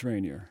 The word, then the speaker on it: Rainier: Do you know about, and I may be saying, how do you pronounce R-A-N-I-E-R? – Depends where you're pronouncing it. If you're in Ohio. – Or, I don't know. – Oh. – But like Prince Rainier.